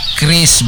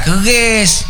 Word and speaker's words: QRIS: Kris, Bu. Kris. Q-R-I-S. Dibacanya kris, bukan keris.